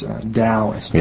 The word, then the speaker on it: Taoism: Actually, the Chinese pronunciation, this is based on an old system of transliteration, but the T is pronounced as D, so it's Taoist.